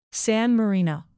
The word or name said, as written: san marino